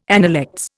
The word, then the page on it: analects: Indian